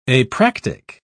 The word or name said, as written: apractic